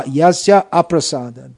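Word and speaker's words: yasya aprasādāt: It should be pronounced yasya prasadat. If you say yasya prasadat, it means by his mercy, nagati katopi, no hope. So it should be pronounced yasya, yasya aprasadat, by his non-prasad.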